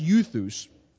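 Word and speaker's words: euthus (εὐθύς): And in the Greek, it's pronounced euthus, and it gets translated sometimes as at once or things like that.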